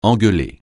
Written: engueuler

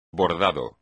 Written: bordado

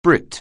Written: Brit

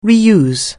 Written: re-use

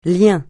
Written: lien

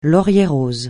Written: laurier-rose

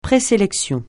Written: présélection

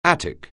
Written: Attic

